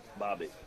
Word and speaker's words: Bobbitt: Bobbitt.